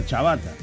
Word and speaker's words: ciabatta: Ciabatta. Se pronuncia chabatta.